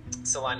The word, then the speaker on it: Salina: Am I saying that right, Salina? – Salina, yeah.